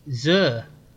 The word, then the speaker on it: Xur: So, I'm sorry, how are you pronouncing that? I mean, it's spelled X-U-R. – Zur, isn't it? – Zur.